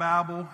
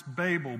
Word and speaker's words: Babel: It's not Babel, it's Babel, B-A-Y-B-U-L-L. That's how you pronounce it, Babel.